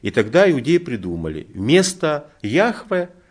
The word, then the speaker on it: Яхве: То есть только примерно где-то с 19 века библеисты восстановили, что сущий, тетраграмматон читался именно как Яхве, это только с 19 века.